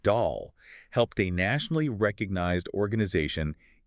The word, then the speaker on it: Daahl: And just a quick note, that is spelled D-A-A-H-L, but it's pronounced Dahl, like the toy.